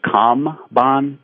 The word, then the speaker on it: Kanban: Is that K-A-H-N-B-A-H-N? – It's K-A-N-B-A-N, and I think it's actually Kamban, pronounced K-A-M-B-A-N, but again, not being Japanese myself, I'm sure I'm mangling that, but that's the way I've heard it pronounced most commonly.